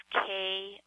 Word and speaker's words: Kay: My English name is Kay Olin. Kay is my first name, K-A-Y, and my last name is Olin.